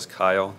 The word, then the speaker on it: Keil: My name is Travis Kyle, that's spelled K-E-I-L, and I represent the appellant, Ms. Camille Dennis Bond.